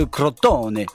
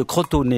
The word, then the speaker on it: Crotone: Oui, on devrait dire d'ailleurs Crotone, puisque cette ville se trouve en Calabre et qu'en 557 avant Jésus-Christ, eh ben, elle s'y trouvait déjà. Milon de Crotone. Faut bien faire gaffe à l'accent tonique, hein, parce que sinon ça fait Milon de Crotone et c'est pour le moins pas très heureux, disons.